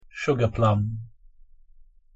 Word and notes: sugarplum: English

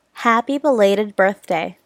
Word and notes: happy belated birthday: English

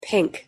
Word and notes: pink: English

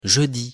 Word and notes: jeudi: French

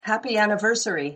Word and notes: happy anniversary: English

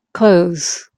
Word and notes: close: English; verb

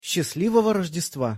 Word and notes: счастливого Рождества: Russian

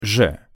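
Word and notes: ж: Russian

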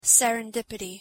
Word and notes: serendipity: English

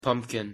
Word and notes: pumpkin: English

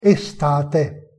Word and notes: estate: Italian

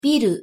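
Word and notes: ビル: Japanese

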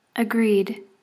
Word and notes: agreed: English

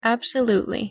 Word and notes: absolutely: English

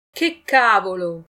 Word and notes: che cavolo: Italian

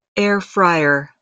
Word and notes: air fryer: English

